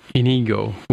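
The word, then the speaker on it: Iñigo: played by Inigo with a umlaut on the end, or not an umlaut, a squiggly on the end there. So I guess it's pronounced in sort of a Spanish way.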